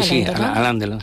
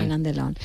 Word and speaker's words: Alain Delon: ¿Lo estoy pronunciando bien? – Sí, Alain Delon. Sí. – Alain Delon.